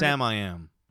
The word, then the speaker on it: Samiam: I don't know if it's pronounced Samiam. – It's Samiam.